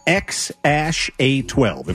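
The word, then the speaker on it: X Æ A-12: And they gave this boy a ridiculous name, which is actually pronounced X-A-12. If you saw how this is spelled, it would drive you crazy. But that's how this is his name, X-A-12.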